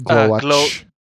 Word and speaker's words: Glowatz: CZ is CH usually. So Glowatz. – Glowatz. And the W is a V sound.